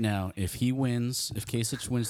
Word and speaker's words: Kasich: It's, it's Kasich.